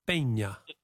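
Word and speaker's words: Peña: Marco Pena with a little accent on the N, Pena.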